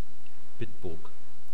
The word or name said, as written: bitburg